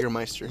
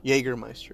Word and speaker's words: Jägermeister: How do you say Jägermeister. – It? Jaeger? – Jägermeister.